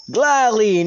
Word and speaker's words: Glalie: Glalie.